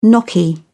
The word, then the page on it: gnocchi: British English